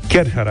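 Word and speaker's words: Kärcher: Kerher. Kerher, așa.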